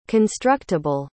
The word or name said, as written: constructable